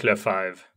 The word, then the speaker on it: Lefebvre: So his name is Eric Lefebvre. – Lefebvre? – Yeah, so the B, you don't pronounce it, basically. It's a silent.